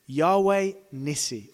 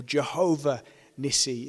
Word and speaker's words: Yahweh Nissi: Yahweh Nissi, or Jehovah Nissi, if you want to use the German pronunciation. But Yahweh Nissi is what we're looking at today.